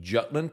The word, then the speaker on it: Jutland: The modern country of Denmark consists of the Jutland Peninsula, and I know that's not how the Danes pronounce it, but that's the way we say it in English, like the Battle of Jutland in World War I.